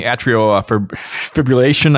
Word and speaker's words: atrial fibrillation: Mary writes in talking about the ECG support on the Apple Watch, diagnosing atrial fibrillation. I can never say that. Am I saying that right? – That word, yeah, totally.